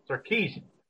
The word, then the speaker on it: Sarkisian: Sarkisian? – Sarkisian.